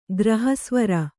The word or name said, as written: ಗ್ರಹಸ್ವರ